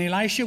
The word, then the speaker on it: Elisha: And Elisha, E-L-I-S-H-A, Elisha, as Diane pronounced.